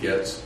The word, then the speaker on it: Getz: My name is David Getz, G-E-T-Z.